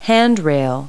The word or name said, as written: handrail